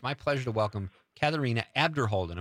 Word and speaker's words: Katharina: The H is silent. – I knew it. – So it's Katharina.